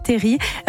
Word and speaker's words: terril: Il faut dire terris et pas terril, là. – Ouais, ça s'écrit terril, mais ça se dit terris.